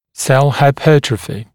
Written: cell hypertrophy

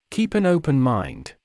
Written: keep an open mind

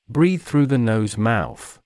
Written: breathe through the nose/mouth

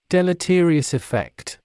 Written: deleterious effect

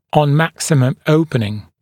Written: on maximum opening

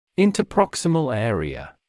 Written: interproximal area

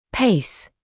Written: pace

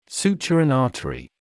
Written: suture an artery

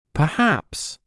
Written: perhaps

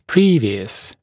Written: previous

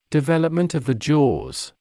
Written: development of the jaws

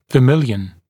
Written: vermilion

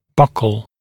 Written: buccal

